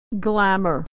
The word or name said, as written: glamour